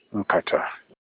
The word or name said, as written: nkata